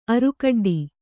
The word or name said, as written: ಅರುಕಡ್ಡಿ